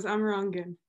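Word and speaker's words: Amerongen: Very close. Amarongen. – Amarongen, of course, the proper Dutch pronunciation.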